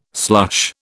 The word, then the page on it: slush: British English